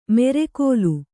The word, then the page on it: ಮೆರೆಕೋಲು: Kannada; mere kōlu